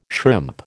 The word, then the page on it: shrimp: American English